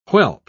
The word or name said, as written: whelp